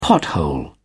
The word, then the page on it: pothole: British English